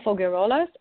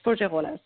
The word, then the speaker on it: Faugerolas: So I just have a Facebook page under my name, Marie, M-A-R-I-E hyphen A-N-G-E, and my name, Fagarolas, F-A-U-G-E-R-O-L-A-S. In French, it's pronounced Fagarolas.